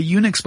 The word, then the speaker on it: Unix: By the way, Unix, by the way, was originally spelled U-N-I-C-S, but because we pronounce that Unix, they just replaced the C-S with an X eventually. So that's why it's spelled U-N-I-X.